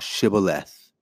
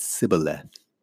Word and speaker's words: Shibboleth: If he said, Nay, then said they unto him, Say now Shibboleth. And he said, Sibboleth, for he could not frame to pronounce it right.